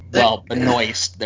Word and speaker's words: Benoist: Is it Benoit? – Yeah. Benoist. There's an S.